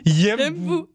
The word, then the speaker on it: Yembu: Mais pourtant c'est deux syllabes. – Dis-moi. – C'est... Yembu. – Yembu. – Yembu. Oui.